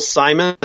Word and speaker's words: Symonds: It's called Apoc Z by Michael Simon, and my last name is spelled Symonds.